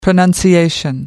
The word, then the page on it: pronunciation: British English